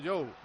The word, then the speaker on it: Zhou: Pero yo he hablado con Zhou y yo le dije, hola, Zhou. Y me dijo, no, Zhou.